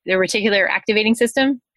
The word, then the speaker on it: reticular activating system: So it's almost like, you know, activating their reticular activating system.